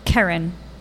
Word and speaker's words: Kerryn: Kerryn.